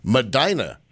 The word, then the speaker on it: Medina: And you were born in Medina, which rhymes with Regina in Saskatchewan.